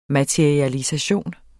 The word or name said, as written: MATERIALISATIONERNES